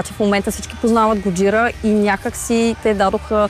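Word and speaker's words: Gojira: Годжира, това е японската дума за Годзила. Знаем, че групата започва именно по това име. Позволявам си да произнасям с френското ударение на последната сричка. Годжира.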